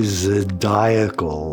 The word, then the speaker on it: zodiacal: I said, what is the zodiacal light? He says, the zodiacal light. I said, oh, I'm mispronouncing it. He said, yeah, that's fragments that come off the sun.